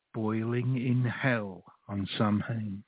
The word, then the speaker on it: Samhain: Samhain is the correct pronunciation, that's correct.